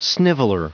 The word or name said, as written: sniveler